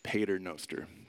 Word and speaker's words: Pater Noster: This is a picture of the church of Pastor Noster. Uh, Pater Noster, that's what it is. Butchered that. and what it does is this place is cool and it's trippy.